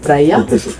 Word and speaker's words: Freya: E infatti Freya, che è la pronuncia corretta, sì, Freya, è la moglie di Odino, quindi siamo nella mitologia norrena.